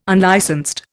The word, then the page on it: unlicensed: Indian